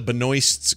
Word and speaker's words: Benoist: Melissa Benoist's Girl of Steel. Is that how you say her name? – Benoist. Yeah, Benoist.